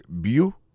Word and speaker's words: beautiful: Gardez pour voir que l'accent tombé sur le premier syllabe là. That was beautiful.